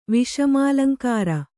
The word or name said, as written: ವಿಷಮಾಲಂಕಾರ